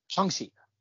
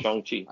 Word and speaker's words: Shang-Chi: Like, I read stuff with Shang-Chi in it. Shang-Chi. I said it wrong. – Shang-Chi.